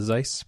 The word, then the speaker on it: Zeiss: It's a Zeiss, pronounced like nice.